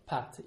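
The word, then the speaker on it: patte: Pat, yeah, with a P. It actually sounds a bit like the word for pasta, which is also kind of pronounced like pat as well.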